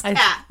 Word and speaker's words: Kat: It's Kat.